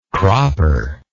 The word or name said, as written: cropper